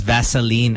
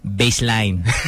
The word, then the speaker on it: Vaseline: Eris says, my tita pronounces Vaseline as baseline.